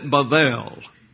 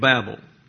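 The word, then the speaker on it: Bavel (בבל): He called it Bavel. Now, that is a Hebrew word where the B sounds like a V, but we come into the English with the pronunciation Babel.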